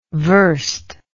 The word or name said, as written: werste